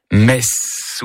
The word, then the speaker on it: Metz: Il s'agit bien sûr du TZ qui finit Metz ou Metz. Ah oui. Metz. Dis-moi comment tu prononces Metz, je te dirai qui tu es. Si tu fais traîner les sifflantes comme le serpent ses sornettes, Metz, risquant de mélanger le nom de la plus belle ville du monde et celui d'un office religieux.